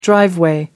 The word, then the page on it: driveway: American English